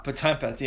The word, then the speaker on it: Potempa: Everybody gets my last name wrong. It's uh, Potempa. – Potempa.